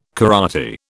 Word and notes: karate: British English

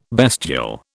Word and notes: bestial: British English

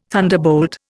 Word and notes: thunderbolt: Indian

